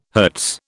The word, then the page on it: hertz: British English